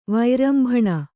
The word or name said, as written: ವೈರಂಭಣ